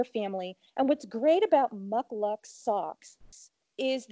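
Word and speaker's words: Muk Luks: Mucklucks, and it's spelled M-U-K, next word, L-U-K-S, Mucklucks.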